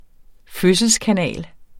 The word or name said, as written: fødselskanal